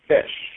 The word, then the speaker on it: ghoti: That's an old one, and G-H-O-T-I is pronounced fish.